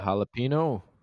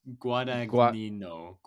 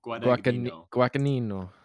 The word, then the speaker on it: Guadagnino: Does anyone else know how to pronounce it? – Jalapeno? – No, it's Guadagnino. – Guadagnino. You gotta say the accent.